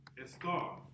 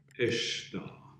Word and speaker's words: Ishtar: In the Book of Ancient Traditions on page 45, it says Easter, this is the English, equals to Estar, It's actually pronounced Ishtar.